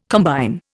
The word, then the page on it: combine: Indian